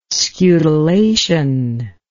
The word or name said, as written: scutellation